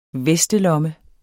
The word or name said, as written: vestelomme